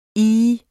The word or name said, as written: ih